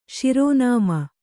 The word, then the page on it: ಶಿರೋನಾಮೆ: śirō nāme